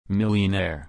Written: millionaire

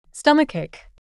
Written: stomachache

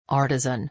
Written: artisan

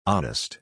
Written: honest

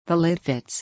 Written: the lid fits

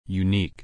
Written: unique

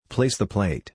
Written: place the plate

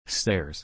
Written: stairs

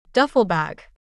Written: duffel bag

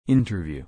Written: interview

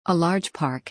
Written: a large park

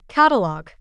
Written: catalog

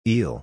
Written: eel

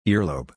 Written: earlobe

